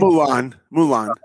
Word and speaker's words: Mulan: Mulan.